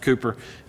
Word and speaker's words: Cowper: It looks like Cowper, but uh, it's pronounced Cooper.